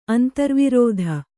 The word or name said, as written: ಅಂತರ್ವರ್ತಿ